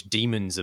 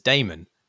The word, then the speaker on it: demon: and also from which demons emerge. Actually, one of the weird things about the game is that the main voice actor pronounces the word demon as Damon, which is, you know, as perennial mispronouncers of important things, it's not for us to correct them.